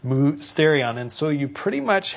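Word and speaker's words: mysterion (μυστήριον): Well, the word mystery in the Bible, and you'll read it a number of times, especially in the New Testament, it represents the Greek word that's pronounced mysterion. So you can hear it. It sounds the same.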